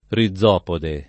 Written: rizopode

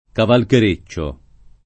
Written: cavalchereccio

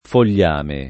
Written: fogliame

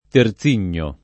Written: Terzigno

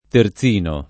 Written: terzino

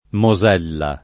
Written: Mosella

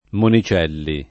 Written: Monicelli

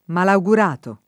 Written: malaugurato

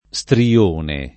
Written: strione

